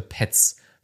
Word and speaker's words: Pets: Da gab es ja auch einige Kommentare, die gesagt haben, du kannst das gerne noch so oft PES aussprechen, wie du möchtest. Das heißt hier Pets. Weil wir sind ja immer noch in Deutschland oder Österreich, besser gesagt, wo Pets ja eigentlich herkommt.